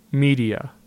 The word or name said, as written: Media